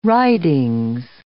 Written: Writings

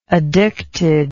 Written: addicted

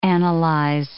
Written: analyze

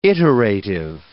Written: iterative

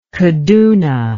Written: Kaduna

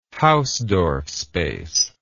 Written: Hausdorff space

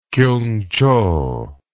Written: kiungchow

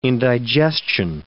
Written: indigestion

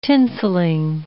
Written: tinselling

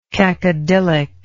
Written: cacodylic

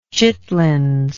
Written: chitlings

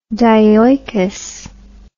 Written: dioicous

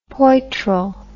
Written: poitrel

